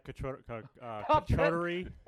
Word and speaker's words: charcuterie: Some Popette charcuterie.